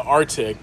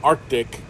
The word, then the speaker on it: Arctic: the Arctic. Or the Arctic, if I want to say it right. – There you go.